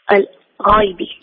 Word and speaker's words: الغيب: الغيب ولا الغيب؟ لقلنا، لقلت لك كلمتان أو نطقان، الغيب الغيب، أيهما صح؟ – الغيب. – أحسنت، الثانية الغين مفخمة، ممتاز، تفضل.